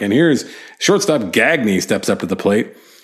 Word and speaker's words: Gagne: Whatever his last name was, Gagne, G-A-G-N-E, but nope, his name was Gagne. That's how he pronounced it.